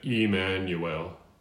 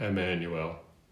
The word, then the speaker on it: Emmanuel: We're going to sing Emmanuel, E. It's a long E, or in the International Phonetic Alphabet, you write an I with a dot over the I. But bottom line is, we're pronouncing it Emmanuel, not Emmanuel, which you probably wouldn't have done anyway.